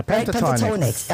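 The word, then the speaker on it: Pentatonix: How do you pronounce this? Pentatonics? – Yeah, pentatonics. Right? – Pentatonics. Uh, yes.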